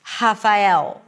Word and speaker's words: Rafael: But for some speakers, they only have a R sound and a H sound, like Rafael. Rafael, like an H sound. So actually, there is an H, it's just pronounced as an R.